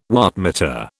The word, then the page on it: wattmeter: British English